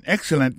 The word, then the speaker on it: excellent: You are an excellent musician. Ou son musicien excellent, ou son excellent musicien. You are an excellent musician. En parlant de accent, on regarde pour nous accent tomber sous premier syllabe là.